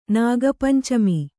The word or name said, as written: ನಾಗಪಂಚಮಿ